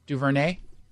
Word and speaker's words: DuVernay: Ava DuVernay, she's a female. Did I say it wrong? DuVernay? – Close enough, I think.